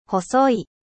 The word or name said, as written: mince